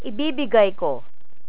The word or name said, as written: ibibigay